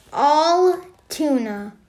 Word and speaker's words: Altoona: If you need help pronouncing it, it sounds exactly how you'd say it. Altoona. But it's not like T-U-N-A. It's T-O-O-N-A.